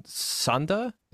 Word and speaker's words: Sanda: then would later start training in Sanda.